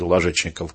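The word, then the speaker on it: Лажечников: И у всех любителей русского Вальтера Скотта, талантливейший писатель Ложечников, конечно, Ложечников, правильно говорить, вот, тоже был такой вроде, что-то вроде шока.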